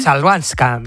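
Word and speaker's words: Chwalińska: Chalbanska.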